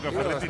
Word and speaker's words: Ferretti: Ricardo Tuca Ferretti. No, lo pronuncié en italiano, lo profesor Ricardo Tuca Ferretti.